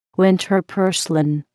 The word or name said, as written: winter purslane